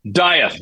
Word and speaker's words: Dieth: Dieth.